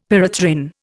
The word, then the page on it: pyrethrin: Indian